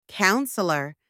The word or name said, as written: counsellor